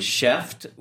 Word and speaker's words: Chef'd: The next company was Cheft, which that's spelled CHEF'D.